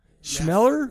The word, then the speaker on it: Schmoeller: S C H M O E L L E R Schmoller. Schmeller? – Yeah, let's go with that.